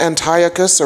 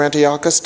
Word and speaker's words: Antiochus: So the king of Syria, King Antiochus or Antiochus, depending on how you want to pronounce it, decided that he didn't like diversity.